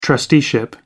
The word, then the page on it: trusteeship: American English